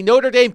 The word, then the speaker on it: Notre Dame: But they did take the step of canceling the book signing, apparently because the author of a book about a Notre Dame coach was on the CFP committee.